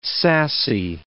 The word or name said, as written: sassy